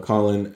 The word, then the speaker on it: Colin: I'd say it would be between like C2, Corinne, and Colin. Sorry, I keep saying Corinne. – That's because of the Japanese pronunciation, not having L's. – Yeah. – Mm-hmm. Yeah. – C2, Colin, and the girl from Gurren Lagann too.